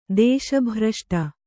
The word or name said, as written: ದೇಶಭ್ರಷ್ಟೆ